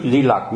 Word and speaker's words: Di Lặc: Thật ra cái danh từ của nó đúng ra mình phải đọc là Mi Lạc mới đúng, chữ tàu á, chữ tàu của nó là Mi Lạc, Nhưng mà không biết tại sao lúc mà mình phiên âm qua tiếng Việt thì mình cứ gì,